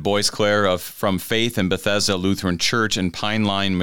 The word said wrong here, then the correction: Pine Lawn: Missouri. Pine Lawn, I haven't said that right today.